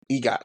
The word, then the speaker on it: EGOT: is that how you say it? Yep, EGOT. Okay.